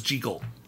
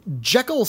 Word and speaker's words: Jekyll: Yeah, as we learn late in the series of Jekyll, the original pronunciation of Jekyll was Jekyll. Which makes it sound a little silly, doesn't it?